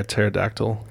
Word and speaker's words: pterodactyl: Why can't you hear a pterodactyl go to the bathroom? – Why can't I hear a pterodactyl go to the bathroom? – Because the P is silent.